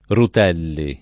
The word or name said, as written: Rutelli